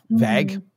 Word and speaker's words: Vague: The core argument of the guy, Richard Vague, I think is how you say his name, V-A-G-U-E. So it's not V-A-U-G-E, but it's, I don't know, Vague. The way you say his name is Vague.